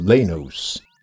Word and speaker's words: Lanos: However, Little Professor Man himself seemed to consistently pronounce the name as Lanos. That is, with a clear N sound rather than an M.